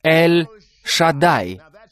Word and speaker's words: Эль-Шадай: Я Эль-Шадай. Это правильное произношение. Не Шадай, не Шадай. Не это.